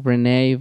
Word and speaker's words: Rene: They can find me on Instagram, Facebook, at Renee Vaca Comedy. My name is spelled R-E-N-E, and Vaca is my last name.